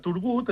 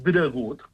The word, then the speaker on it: Turgut: I els llatins, per referir-se a Turgut, acabaven pronunciant dregut, clar.